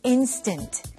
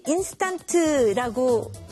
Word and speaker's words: instant: All right, so the first word is instant. Instant. 네, 한국 분들이 인스턴트라고 이렇게 발음을 하죠. 원어민들은 instant라고 합니다.